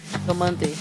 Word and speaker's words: Diomandé: Como é que é a pronúncia? Diomandé? – Diomandé.